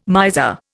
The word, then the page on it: miser: Indian